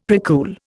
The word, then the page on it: precool: Indian